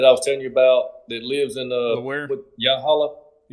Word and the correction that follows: Yalaha: Uh, is that how you say it? – So Yalaha. his yeah, Yalaha.